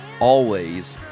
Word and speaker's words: Alvvays: It's spelled Always, A-L-V-V-A-Y-S.